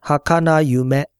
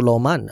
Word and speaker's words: 儚夢: 你去查字典的话，它的读音是 “hakana yume”，梦是 yume，大家很清楚了。“ 萌” 字可能稍微少用一点，就是是读 “hakana”。无论如何，不可能读 “loman” 的。